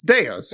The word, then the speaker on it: deus: Wait, did you just pronounce it deuce? – Yeah, because it's deuce? All shit. It's deus okay. ex machina, but I've always said deuce ex machina.